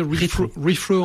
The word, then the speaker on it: Rethrow: On prononce rétro en français, refro en anglais, qui a un double sens dans le mot.